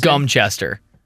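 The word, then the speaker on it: Godmanchester: Gumster, Cambridgeshire, England is spelled G-O-D-M-A-N, Chester. God Manchester is pronounced Gumchester.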